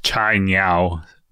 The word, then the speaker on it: Cainiao: Yeah, I think it's Chai Niao would be the correct pronoun, or somewhat correct. I mean, I'm sure we have some Mandarin speaker. It's spelled C-A-I-N-I-A-O. So however you say that.